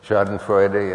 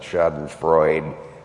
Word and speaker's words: Schadenfreude: Schadenfreude, yeah. And thank you for making it four syllables and not Schadenfreude, the way you hear it all the time.